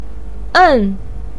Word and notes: en: 4

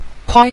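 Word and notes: kuai: tone 5

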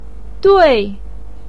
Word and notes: dui4: pinyin

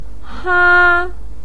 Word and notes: ha1: pinyin

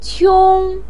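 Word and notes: qiong1: tone 1

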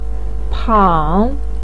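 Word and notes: pang3: tone 3

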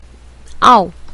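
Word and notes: ao: tone 4

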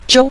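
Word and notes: jiu: tone 5

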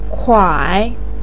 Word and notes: kuai: 3

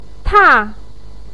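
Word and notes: ta: tone 4

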